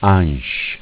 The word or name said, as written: Ansc